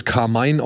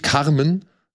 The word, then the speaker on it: Carmen: Ich dachte eigentlich, er wird Carmine ausgesprochen, so, ja, aber gut. Ja. Äh, er heißt tatsächlich Carmen, ja, ähm, wacht in seiner Küche auf und hat von Sekunde eins an erstmal Stress.